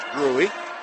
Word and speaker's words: Grewe: G-R-E-W-E, pronounced Gruey, like it is in the Upper Miami Valley.